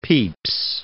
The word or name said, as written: Pepys